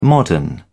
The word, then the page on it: modern: British English